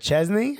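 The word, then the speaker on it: Szczęsny: I think Wojciech set Chesney. Am I saying it correctly? – Yes.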